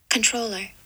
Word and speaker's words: Comptroller: Okay, so uh, and if you were wondering, I'll just put controller. Did you hear that? Yeah. Okay. So that's how you pronounce it, in case anyone was oh, okay.